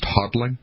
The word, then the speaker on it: hodling: I believe it's pronounced hodling.